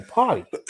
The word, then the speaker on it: Pardi: Party. – Party. – Is he a rap or some shit? – But it's spelled like P-A-R-D-I.